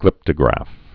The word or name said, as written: glyptograph